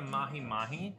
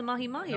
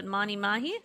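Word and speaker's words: mahi-mahi: Did I say it wrong? Was it Mani Mahi? – It's the... Yeah, I guess it is. – Mahi Mahi. – Mahi. Like the fish.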